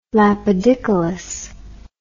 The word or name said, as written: lapidicolous